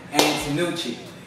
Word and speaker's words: Antonucci: Ladies and gentlemen, Lorenzo Antonucci.